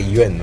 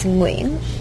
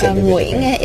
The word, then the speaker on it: Nguyen: But when they pronounce Nguyen, it's like Yuen, right? Anyway. – The Vietnamese pronunciation is Nguyen. – Yuen. Oh, it's Nguyen. It does this weird curly thing with your tongue.